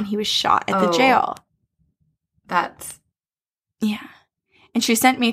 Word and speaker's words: gaol: But G-A-O-L. It's just an old spelling of jail, so it's pronounced the same way as jail.